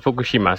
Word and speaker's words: Fukushima: Ja, nou, de, de Japanners die leggen niet echt de klemtoon. Dus ik zou zo gewoon eh, Fukushima zeggen. Eh, dus vooral zonder, zonder klemtoon op een, een van de delen.